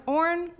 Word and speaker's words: Orn: As always, we have our sponsees, and those are Hannah Baker, Sam Orn, at Sam Orn, Orn like corn.